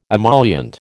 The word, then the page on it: emollient: American English